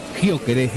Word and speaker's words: Gyökeres: Ya viene Paul Bucci, nos queda el último, nos queda el goleador de Suecia, nos queda nada más y nada menos que Gyökeres, ¿lo digo bien? – Exactamente, Víctor Gyökeres, 1.89 m para él.